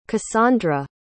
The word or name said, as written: cassondra